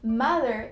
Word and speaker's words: mother: Primeira dica de pronúncia tá aí. Mother, eu quero que você fale com um som mais suave, ficando mais ma, ma, um som de A, mother.